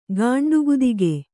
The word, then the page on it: ಗಾಂಡುಗುದಿಗೆ: gāṇḍu gudige